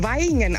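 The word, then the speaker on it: Vaihingen: Es wird immer Weingen ausgesprochen.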